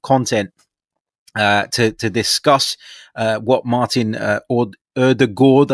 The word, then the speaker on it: Ødegaard: It's actually pronounced Ødegaard. And I got that on good authority yesterday from Norwegian journalist Jonas Jæver.